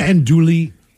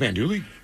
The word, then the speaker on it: Andouille: Ratatouille screwed up Andouille? – Well, just now we see something spelled like that, you think you got to announce the Tui part.